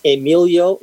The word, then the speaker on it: Emilio: My name is Emilio Valdez. That's spelled E as in Edward, M-I-L-I-O. Last name is Valdez, V as in Victor, A-L-D-E-S at the end, not a Z.